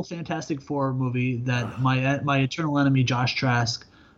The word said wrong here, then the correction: Trank: Josh Trank.